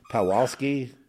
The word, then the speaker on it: Pawlowski: Pawlowski, how do you pronounce it? Pawlowski. Okay, anyway, he was a beautiful soul.